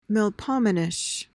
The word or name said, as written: melpomenish